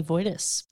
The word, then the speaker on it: Wojtas: Spelled W-O-J-T-A-S. Looks correct. Like Wojtas. – Wojtas, yes. I get Wojtas a lot. – Wojtas. – Everyone assumes the J is like Mexican.